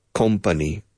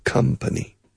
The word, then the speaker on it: company: All my life, I hear my Spanish friends say, company. It's not company, it's company. It rhymes with come.